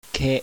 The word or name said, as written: KE